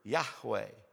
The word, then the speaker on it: Yahweh: If in English we pronounce the three Hebrew letters that come together to make this word, Yahweh.